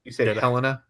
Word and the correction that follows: Hela: It's Hela. Preview for Thursday.